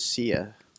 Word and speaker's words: Sia: Yeah, but I think Fluffy was big on DAO, or Psy. – I've always heard of Sia. – Sia, okay. Sia. I was pronouncing it wrong. Okay, Sia. – Yeah. – How's it spelled? Sia Coin. – S-I-A.